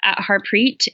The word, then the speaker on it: Harpreet: So you can email me, and you're welcome to put it at the bottom of the link as well, at Harpreet, HARPREET.Uppal, my last name, UPPAL.